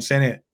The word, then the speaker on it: Sinnott: But there is a big group of guys, and you named a few of them, especially like Ben Sinnott.